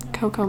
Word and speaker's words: Kokomo: To Kokomo. – Yeah. – It sounds like coconut, but, like, not actually. – It's spelled with a K. Two Ks. Kokomo. – Okay, spell this out, because I'm K-O-K-O-M-O. Oh, M-O. I don't know why I said that. So, K-O-K-O-M-O.